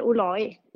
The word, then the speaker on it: Olai: Du, Sara, du får själv säga, hur ska vi uttala ditt efternamn? – Vi säger Olai.